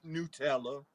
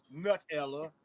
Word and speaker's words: Nutella: And the correct pronunciation is Nutella, but our version is Nutella, and there we go.